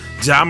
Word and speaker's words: Ja: And I said Jay twice, I think, and then I cleaned it up and said Ja. So it's pronounced Ja Morant.